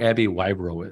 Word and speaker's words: Abbie Wyro: And I think we're going to be able to find out a little bit more about who Abbie Wyro is.